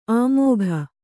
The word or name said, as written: ಆಮೋಘ